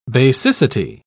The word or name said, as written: basicity